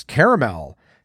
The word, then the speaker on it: caramel: Caramel is the original way and still the preferred way. Although caramel, which was once a Midwestern regional pronunciation, is also acceptable.